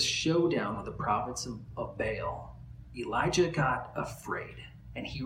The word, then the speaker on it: Baal: where Elijah fought the prophets of Baal, or Baal, as some people pronounce it, 400 of these prophets.